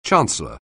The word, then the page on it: chancellor: anglais